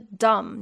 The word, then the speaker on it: dumb: 或者是像是 dumb，你要骂一个人啊，你好笨哦，d u m b，那个 b 也是一样不发音的。